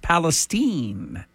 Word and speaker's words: Palestine: Spelled like Palestine, but pronounced Palestine.